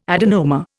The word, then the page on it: adenoma: Indian